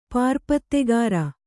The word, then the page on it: ಪಾರುಪತ್ತೆಗಾರ: pārupattegāra